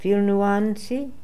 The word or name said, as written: Filnooanzi